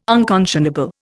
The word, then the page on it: unconscionable: Indian